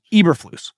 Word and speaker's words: Eberflus: Do you remember when you used to call this guy Uberflus? I used to call him Uberflus. And then it turns out his name is actually pronounced Eberflus.